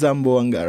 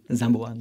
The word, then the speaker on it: Zamboanga: So it's Zamboanga, right? – Zamboanga. – Yes. It's in the southwestern tip of the peninsula of Zamboanga in Mindanao.